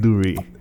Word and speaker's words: Dhoray: Daren Dhoray.